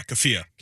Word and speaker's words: keffiyeh: Here's a white girl wearing, how do you say it, a keffiyeh? – Yeah, keffiyeh. – Keffiyeh, yeah, that is the headscarf. And this is from one account of the protest. And she's wearing a keffiyeh.